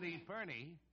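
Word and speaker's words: epergne: Epernie. – It isn't Epernie. Well, this is Christmas. I'll pronounce it. Epern.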